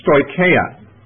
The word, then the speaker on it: stoicheia: There's also reference in this epistle, as in a few other places in Paul's writings, to a Greek term called stoicheia. Let me tell you how to spell it. It's S-T-O-I-C-H-E-I-A, stoicheia.